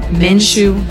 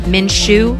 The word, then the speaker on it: Minshew: How do you say his name? – Minshew. – Minshew. Minshew. Minshew. Minshew. It sounds like, like a, a salad shoe. – Like a men's shoe.